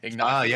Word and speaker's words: Ignacio: And knockout, performance of the night, how do you say his name? Ignacio. – Ignacio.